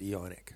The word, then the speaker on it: eonic: They are therefore aeonic markers, or eonic markers, excuse me. You should pronounce it eonic, of course.